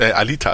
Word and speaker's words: Alita: Die Geschichte sieht sehr interessant aus, bestimmt auch nicht unbedingt, Alita, also nicht Alina, habe ich Alina gesagt? Ich habe mich Alita gesagt. Nee, du hast, glaube ich, richtig gesagt.